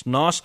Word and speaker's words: Noss: All there than to say that Noss, G-N-O-S-S, how you spell that, but that's not how you pronounce it. It's not Gnoss, it's Noss. The Celtic music band are just having a blistering piper in there.